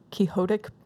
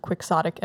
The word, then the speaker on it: quixotic: And side note, you just said quixotic to make it sound like Don Quixote. It's typically pronounced quixotic, and we know that, and you don't have to write in.